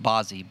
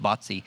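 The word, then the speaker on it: Bazi: B-A-Z-I. I always thought it pronounced Bozzy, but Phil tells me it's pronounced Botsy.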